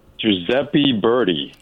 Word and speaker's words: Giuseppe Verdi: Giuseppe Verdi.